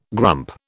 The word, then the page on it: grump: British English